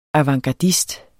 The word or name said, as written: avantgardist